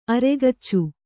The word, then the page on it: ಅರೆಗಚ್ಚು: aregaccu